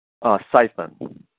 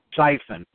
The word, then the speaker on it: S.Y.P.H.E.N.: So, for you, when you started thinking about, and you know, I've got the, how do you pronounce the title of your book? Because I don't want to mispronounce the acronym that you have there. – S.Y.P.H.E.N. – S.Y.P.H.E.N., okay.